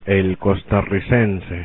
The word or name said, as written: costarricense